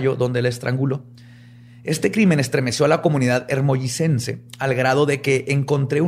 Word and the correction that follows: hermosillense: ¿Pero te dijiste hermollicense? – Se dice así. Hermosillense, perdón. – No, es que me quedé trabado ahí. – hermosillense Es hermosillense.